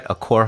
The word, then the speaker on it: Accor: Accor Hotels? – Yep.